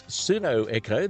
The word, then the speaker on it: Pseudo: It is Pseudo Echo. There is a P at the start. Some folks do call it Pseudo Echo. But we'll stay with Pseudo Echo. The silent P can catch you out.